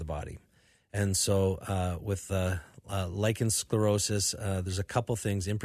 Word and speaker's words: lichen sclerosis: Tell me about lichen sclerosis, L-I-C-H-E-N, lichen sclerosis.